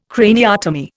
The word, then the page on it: craniotomy: Indian